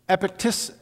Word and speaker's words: Epictetus: It was, here he is, Epictetus, that's how it's pronounced.